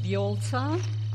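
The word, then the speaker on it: Vjolca: My name is Violza. I'll spell it for you. It's Victor, Juliet, Oscar, Lima, Charlie, Alpha.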